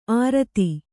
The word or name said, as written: ಅರ್ಥಿ